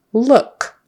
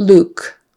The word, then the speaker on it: look: Then we have look. Make sure you get your vowel, not oo. We don't want Luke. Luke. That's a person's name. L-U-K-E.